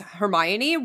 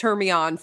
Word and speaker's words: Hermione: And also learning how to actually pronounce Hermione was wild when that happened. – Yeah, fair. Yeah, no, agreed. That's so funny. – Definitely read it as Hermione for like four books.